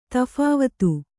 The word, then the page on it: ತಫಾವತು: taphāvatu